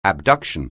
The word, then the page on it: abduction: anglais